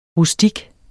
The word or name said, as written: rustik